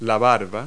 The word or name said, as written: wattle